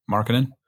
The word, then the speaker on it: Markkanen: How do you say his last name again? Markkanen? – Markkanen.